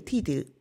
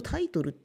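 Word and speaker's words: Titel: そして、ティーテル、ティーテルと言っていますけれども、なんか英語だとタイトルって読めてしまうので、本の題名のことかしらなんて思ってしまうかもしれませんが、こちら、学位という意味です。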